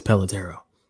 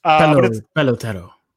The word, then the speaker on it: Pelotero: Am I saying that right? Pelotero? It feels weird to say. Whatever. I think it's Pelotero. – It is Pelotero. – Okay. I want to make sure. – Pelotero.